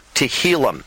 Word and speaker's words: Tehillim: And that is the conclusion in this recording of the Songs of David, Psalms, pronounced in Hebrew, Tehillim.